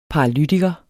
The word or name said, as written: paralytiker